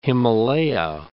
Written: the Himalaya

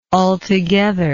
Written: altogetherness

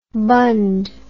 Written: bunned

